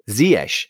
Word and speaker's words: Ziyech: We've been told Ziyech, how to pronounce it. Ziyech.